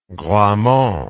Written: Gramont

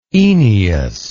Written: Oeneus

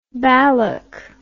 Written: ballock

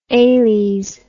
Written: ales

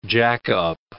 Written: jack-up

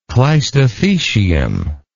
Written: cleistothecium

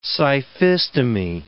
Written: scyphistomae

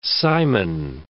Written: Simon

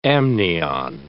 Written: amnion

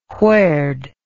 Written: whered

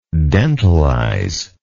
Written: dentalize

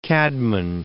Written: Caedmon

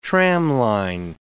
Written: tramline

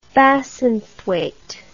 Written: Bassenthwaite

